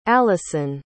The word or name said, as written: allyson